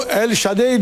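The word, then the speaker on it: Chadaille: Bu El Shaddai diyor. Shaddai de olur, Shaddai de olur bu. A-I-E okunur Fransızca'da. El Shaddai. O Shaddai de diyebilirsiniz diyor. Yani biz ne diyelim? – Yani. El Shaddai adı aslında. – Shaddai diyelim peki.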